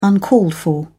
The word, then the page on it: uncalled-for: British English